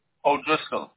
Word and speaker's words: O'Driscoll: O'Driscoll. – How do I spell that, please? – O-D-R-I-S-C-O-L-L.